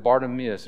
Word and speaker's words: Bartimaeus: Bartimaeus, that's how you pronounce his name. Probably heard it pronounced in many different ways. But the story of Bartimaeus, and we'll see what his weaknesses are right off the bat.